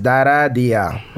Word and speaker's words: Dara Día: Ah, mi nombre de verdad es Dara Día. O sea, lo he dicho bien. Sí, Dara, mi nombre, Día, mi apellido como tenda día.